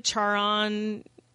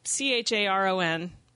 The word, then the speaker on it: Charon: Some people pronounce it Charon. It's Charon. It's the ferryman of the underworld.